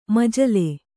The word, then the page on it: ಮಜಲೆ: majale